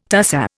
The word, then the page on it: tussah: Indian